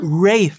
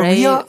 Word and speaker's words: Rhea: Weiß man, ob Rhea spricht man den Vornamen aus, oder? – Ray, wie ich jetzt gelernt habe. Ich sag immer Rhea, aber Ray.